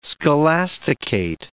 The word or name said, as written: scholasticate